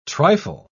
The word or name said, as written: trifle